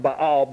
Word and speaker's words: Baal: Astarte was the consort of Baal, B-A-A-L, which is technically pronounced Baal, but everybody says Baal, so we'll say Baal.